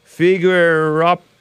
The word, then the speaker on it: Figueirópolis: He was wanted in Brazil with connection with the slaying of a man in his small town of Figueirópolis. I butchered that name. Figueirópolis. I feel like I'm trying to pronounce diminutive. Hey, I think I pronounced it right this time.